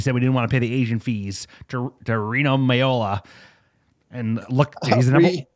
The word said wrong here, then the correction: Mino Raiola: You said Reno Miola. – What is his name? Mino Raiola? Yeah, I mixed it up. I got dyslexia. So Mino Raiola.